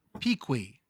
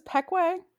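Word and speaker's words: Pequea: Folks, this is Pequay. This is actually where I'm like really, really from. It is in Southern Lancaster County. I had to correct Johnny Gilbert's pronunciation of this word. – It's Pequay. – Pequay. – Like Payway? – No, like Pequay.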